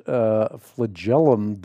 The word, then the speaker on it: flagellum: I cannot say this word. It is F-L-A-G-E-L-L-U-M. I've said it a million times on the Bible Answer Man broadcast, and it is the very example used, flagellum, is how you say it.